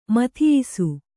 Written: ಮಥಿಯಿಸು

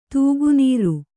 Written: ತೂಗುನೀರು

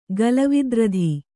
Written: ಗಲವಿದ್ರಧಿ

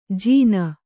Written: ಜಿನ

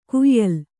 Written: ಕೋಯಿಲು

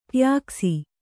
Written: ಟ್ಯಾಕ್ಸಿ

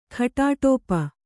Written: ಘಟಾಟೋಪ

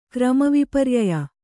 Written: ಕ್ರಮವಿಪರ್ಯಯ

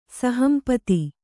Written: ಸಹಂಪತಿ

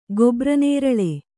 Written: ಗೊಬ್ರನೇರಳೆ

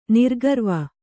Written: ನಿರ್ಗರ್ವಿ

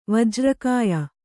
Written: ವಜ್ರಕಾಯ

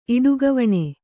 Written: ಇಡುಗವಣೆ

